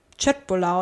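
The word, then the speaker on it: köttbullar: Dass du die eigentlich wie ich glaube, Chöt, Chötbulla oder so aussprechen müsstest.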